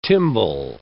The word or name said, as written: tymbal